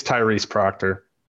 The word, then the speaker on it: Tyrese Proctor: They've also going to be missing their other guy, which is, I believe, Scott Proctor. Did I get his name right? No, wait. No, it's Tyrese Proctor.